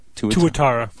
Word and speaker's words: tuatara: Tuatara, am I pronouncing that right? – Tuatara.